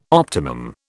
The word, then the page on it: optimum: British English